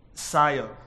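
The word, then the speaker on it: sayo (σείω): Now, interestingly, in verse 10, where it says the whole city was stirred, the word there is, it's actually, the word that's translated stir is pronounced like this, sayo.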